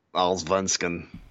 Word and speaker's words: Allsvenskan: You know, he bounced around a little bit between the SHL, the Super Elite, and the Allsvenskan. That's how they say it. Allsvenskan.